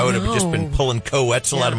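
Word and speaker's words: Koe Wetzel: Yes, rhymes with pretzel. Co-Wetzel.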